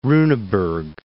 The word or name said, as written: Runeberg